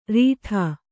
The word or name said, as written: ರೀಠೆ